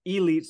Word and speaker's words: Elete: Gear up with Elite Sports. That's ELETE.